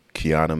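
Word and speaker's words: Quiana: So the situation I'm talking about is a 44-year-old mother, Kiana Mann. I think I'm saying her name right. It's Q-U-I-A-N-A, Mann, with two N's.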